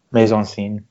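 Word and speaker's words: mise-en-scène: Is it mise-en-scene? – Mise-en-scene, yep.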